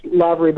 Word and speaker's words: Lavery: You know, I actually appreciate the honesty because often it's pronounced Lavery, but really it's pronounced Lavery. So okay.